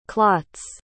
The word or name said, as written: clots